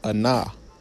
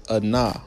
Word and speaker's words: anah: It says humble, which in Hebrew is pronounced anah, anah.